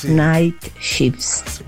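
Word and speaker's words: Nightshift: E vorrei stamattina ascoltare un brano dei Commodores, Night Shifts.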